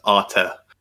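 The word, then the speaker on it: Arte: It is Arta. So it's basically, it's the Italian for art. And so given that we have an Italian working in our office, we should be very careful how we pronounce this one, I guess. – So basically, folks, there is an accent on the E. So Arta, yeah.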